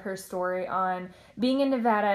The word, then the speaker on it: Nevada: And it's so good because she came from Nevada. I said it right, right? – Good job. You did.